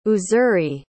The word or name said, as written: uzuri